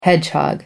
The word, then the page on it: hedgehog: American English